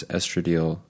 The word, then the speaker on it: estradiol: In the study, urine samples were collected from 33 women over 15 days, and estradiol, progesterone, and testosterone levels were measured. The women and their partners also filled out surveys every night indicating their relationship satisfaction and their thoughts about their partner. They found that on days where women's estradiol, how do you say that? Estradiol.